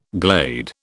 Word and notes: glade: British English

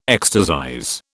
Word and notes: ecstasize: British English

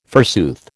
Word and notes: forsooth: American English

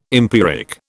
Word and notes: empiric: British English

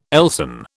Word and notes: Elsan: British English

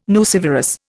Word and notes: nucivorous: Indian

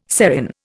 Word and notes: serin: Indian

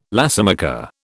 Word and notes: lacemaker: British English

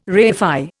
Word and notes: reify: Indian

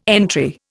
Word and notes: entry: Indian

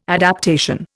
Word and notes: adaptation: Indian

